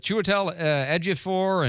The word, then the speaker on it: Chiwetel Ejiofor: I'm impressed with your pronunciation on Chiwetel Ejiofor. That's not an easy name.